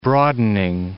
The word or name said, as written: broadening